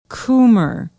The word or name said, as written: Coomer